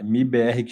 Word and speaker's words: MIBR: Vai ser um prazer aqui descobrir um pouquinho mais da, do trabalho da MBR me corrija se eu estiver pronunciando errado. É MIBR que chama mesmo ou tanto faz? A pronúncia tá perfeita.